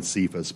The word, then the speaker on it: Cephas: So even though these four parties, the two major parties were in the division were Apollos, and I keep saying Cephas, but it's a hard C.